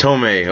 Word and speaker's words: Tomei: Marissa Tomei, is that how you say her name? Or did I butcher that too? – Marissa Tomei. – Tomei, okay.